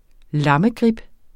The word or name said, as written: lammegrib